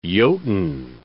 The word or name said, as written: Jotun